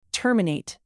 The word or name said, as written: terminate